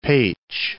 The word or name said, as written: Pecs